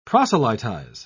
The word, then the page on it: proselytise: British English